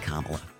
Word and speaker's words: Kamala: Except, it's pronounced Kamala.